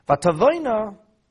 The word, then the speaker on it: Vatavoina: Vatavoina, spelled with the extra yud, which we don't pronounce.